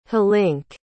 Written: hlink

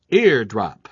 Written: eardrop

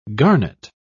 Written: garnet

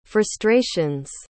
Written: frustrations

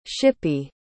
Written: shippy

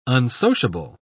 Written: unsociable

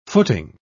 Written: footing

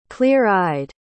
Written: clear-eyed

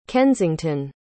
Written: kensington

